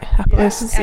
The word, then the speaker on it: Appaloosas: She raised Appaloosas. – Cool. – Am I saying that right? – Appaloosas, yes.